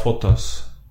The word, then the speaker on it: Fotas: Är det så du uttalar ditt efternamn? – Eh, ja, Fotas. – Fotas. Eller Fotas. I Sverige säger de ju Fotas.